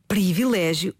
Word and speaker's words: privilégio: Ah, mas antes disso, Filomena, de facto a palavra escreve-se privilégio e deve pronunciar-se em contexto formal, em registro de cuidado, de acordo com a norma padrão, privilégio.